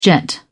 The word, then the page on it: jet: British English